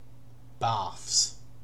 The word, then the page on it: barfs: British English